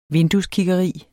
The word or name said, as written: VINDUESKIGGERI